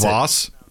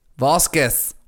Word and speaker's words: Vosges: V-O-S-G-E-S. Vosges. – It's not even close. – Voss. – It's not even close. – Voss. Voss. It's just the G, because it's German, that you swallow the G, so it's Voss.